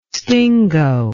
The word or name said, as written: stingo